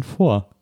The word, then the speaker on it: Fort: Ich glaube, obwohl man sagt auch Fort Knox, ne? Da spricht man das T. Aber eigentlich ist es doch leise. – Vielleicht nur, weil wir doof sind. – Das ist doch eigentlich ein Vor.